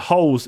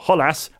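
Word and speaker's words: Holeš: the Czech Republic could really exploit the holes in the Dutch defence. And speaking of holes, it was a man called Holes, or Hollas as it's pronounced, who did the damage.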